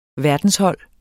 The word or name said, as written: verdenshold